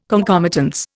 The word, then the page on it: concomitance: Indian